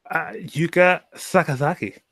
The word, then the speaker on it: Yuka Sakazaki: Yuka Sakazaki.